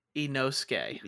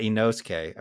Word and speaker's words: Inosuke: Inosuke. – Yeah. Inosuke.